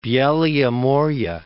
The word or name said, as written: Beloye More